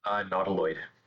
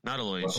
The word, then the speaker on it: Nautiloid: Nautiloid. – Thank you. Nautiloid ship. Four colors for a 5/5 vehicle as flying, and when Nautiloid ship enters the battlefield, exile target player's graveyard. When Nautiloid ship deals combat damage to a player,